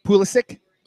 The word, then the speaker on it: Pulisic: Oh, Pusilic. – Yeah. Okay. We just have random – You say tomato. – I say Pusilic. – Pulisic. – That's probably Pulisic. That's probably how it's spelled.